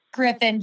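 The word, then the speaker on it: Gryffin: Our sons are 22 and 32, and they also very much like Griffith. Did I say that right? – Griffin, I think it's G-R-Y, and how do you spell it? Let me look. It's G-R-Y-F-F-I-N.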